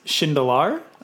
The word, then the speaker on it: Shindelar: So pronunciation, it's my last name is Shindelar. So close, but not quite. And then Ozio, we say Ozio Labs, O-S-I-O.